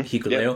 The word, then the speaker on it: Hikuleo: Nichols and Hikuleo. Am I pronouncing that right? – Mm-hmm. – Hikuleo. Yep.